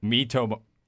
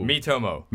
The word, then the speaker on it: Miitomo: Can Niantic stop Pokemon Go from ending up like another draw something or its close relative Miimoto? Miitomo. – Ooh. – Miitomo.